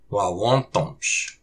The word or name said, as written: Wáwôtamsh!